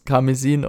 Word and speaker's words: Karmesin: Also, aber immer noch mehr als ähm, Karmesin, spricht man das überhaupt so aus? Karmesin und Popo. – Ja, ja. also oh. das ist tatsächlich, glaube ich, einfach der deutsche Name.